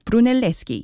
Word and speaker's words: Brunelleschi: which was designed by Filippo Brunelleschi. I hope I'm saying that right. Brunelleschi, yes.